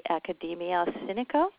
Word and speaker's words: Academia Sinica: You're a research fellow at the Academia Sinica, is that how you pronounce it? – Yes.